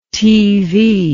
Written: teevee

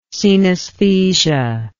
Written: cenesthesia

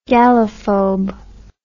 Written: gallophobe

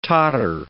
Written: totter

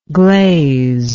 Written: glaziness

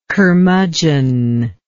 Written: curmudgeon

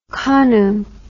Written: kanu

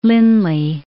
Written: Lindley

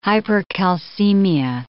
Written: hypercalcemia

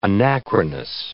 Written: anachronous

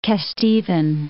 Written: Kesteven, Parts of

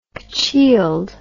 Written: cochealed

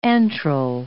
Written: antral

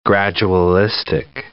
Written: gradualistic

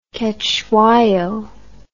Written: Cetshwayo

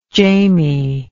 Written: jamie

